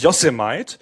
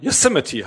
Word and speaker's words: Yosemite: Ich habe ja auch lange gebraucht, bis Apple die neue Betriebssystemversion rausgebracht hat, dass es nicht Yosemite, sondern Yosemite heißt. Diese Gegend da in Amerika.